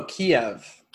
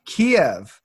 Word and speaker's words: Kiev: Uh, is it Cave? Oh, Kiev. – Kiev.